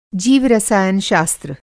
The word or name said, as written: जीवरसायन शास्त्र